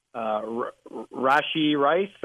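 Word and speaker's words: Rashee Rice: Rashi Rice, I believe is how you say the rookie's name.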